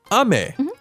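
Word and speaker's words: 雨: Ame da yo. – Ame, going from top to bottom, like rain. It's pronounced like this. Ame.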